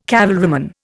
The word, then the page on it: cavalryman: Indian